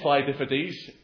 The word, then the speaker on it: Phidippides: And I think it's Phidippides from the way that some American man said it. So it is Phidippides.